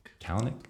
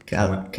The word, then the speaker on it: Kalanick: So last week they had an episode about Uber, and I never know how to say his last name, Travis. – Kalanick? – Kalanick. Kalanick.